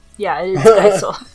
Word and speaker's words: Geisel: It's Theodore Seuss Ted Gazelle. I think I'm saying his last name wrong. – Giesel? – Giesel. Is that better? – How is it spelt? – G-E-I-S-E-L. – Geisel.